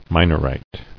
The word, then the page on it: Minorite: Mi·nor·ite; Mi"nor*ite